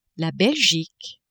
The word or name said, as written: Belgique